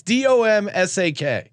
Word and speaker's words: Domask: You would pronounce Domas. It's Domsack.